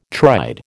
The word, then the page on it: tried: American English